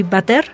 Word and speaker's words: Vader: Y Vader, Vader, si lo convertimos en alemán, le quitamos la T y se pronuncia así, Vader.